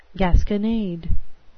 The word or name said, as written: gasconade